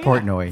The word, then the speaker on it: Portnoy: Portnoy. Am I saying that right? – Oh, yeah. Portnoy.